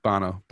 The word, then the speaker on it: Bono: Remember pride with U2 and Boner? – Mm-hmm. Bono, but...